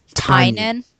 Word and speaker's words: Tynion: Did you know that all these years you've been mispronouncing it, and it's Tynan? – I know.